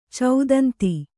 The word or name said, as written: ಚೌದಂತಿ